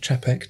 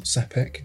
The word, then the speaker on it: Czapek: Um, talking of Bobby Dazzlers, uh, Czapek, which I learned is called pronounced Chapek, not Sapek.